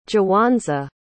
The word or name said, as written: jawanza